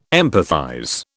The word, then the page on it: empathize: British English